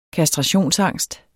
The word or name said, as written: kastrationsangst